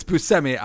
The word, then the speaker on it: Buscemi: It's Buscemi. I thought it was Buscemi, but nope, it's Buscemi.